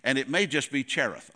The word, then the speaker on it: Cherith: It's spelled C-H-E-R-I-T-H. And it looks like Cherith, like cherish. But I've always called it Kirith.